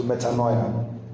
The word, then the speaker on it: metanoia: M-E-T-A-N-O-E-I-N. Who can help me pronounce it? M-E-T-A-N-O-E-I-N. He said, how do we call it? As you heard it. So metanoia.